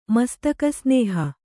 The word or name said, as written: ಮಸ್ತಕಸ್ನೇಹ